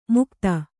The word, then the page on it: ಮುಗ್ಧ: mugdha